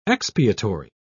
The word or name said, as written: expiatory